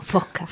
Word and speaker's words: Fosca: Tercer premio Lumen de novela por tu primera novela, Fosca, que creo que se pronuncia Fosca. – Sí, en murciano genuino, Fosca.